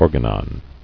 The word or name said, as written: Organon